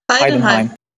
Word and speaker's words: Heidenheim: Heidenheim heter Hur uttalas det? Heidenheim. Bra. Du talade rätt från början, jag skulle aldrig ha tvekat.